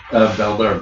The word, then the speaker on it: Beldar: Is it Baldur? – Beldar Brutal. – Beldar. Beldar. How do you spell that? – B-E-L-D-A-R. – B-E-L-D-A-R.